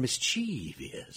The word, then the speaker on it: mischievous: I'm going to do something evil and mischievous. – Yeah. – Like, you know what? It sounds like something out of a terrible movie. So, but mischievous, there's no long vowel sounds there.